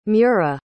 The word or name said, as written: mura